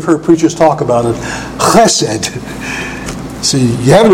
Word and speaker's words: chesed: They usually say chesed or something like that, but it's not. It's chesed. It's a strong vowel, chesed, with a guttural.